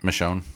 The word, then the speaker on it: Michonne: Michonne.